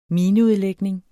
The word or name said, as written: mineudlægning